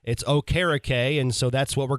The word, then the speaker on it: Okereke: I was going to get into this later in the week, but since you brought it up, he's changed his pronunciation for his last name to Okereke, and he's honoring his Nigerian descent.